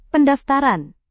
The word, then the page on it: pendaftaran: Indonesian